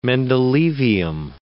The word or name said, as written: mendelevium